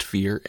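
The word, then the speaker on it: Feyr: That's F-E-Y-R, but it's pronounced Fear, and it's also all about fear.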